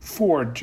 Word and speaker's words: Foard: Ford, it sounds just like the car when you pronounce it, but it's spelled F-O-A-R-D.